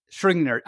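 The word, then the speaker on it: Schrödinger: Schrodinger. I can't say that. How do you say it again? I always mispronounce it. – Repeat after me. Schro. Ding. – Ding. – Schro ding. There you go.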